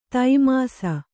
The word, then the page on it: ತೈಮಾಸ: taimāsa